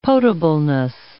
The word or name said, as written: potableness